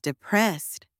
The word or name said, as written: depressed